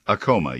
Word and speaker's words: Acoma: Is Acoma the right pronunciation? – Acoma, yes, sir.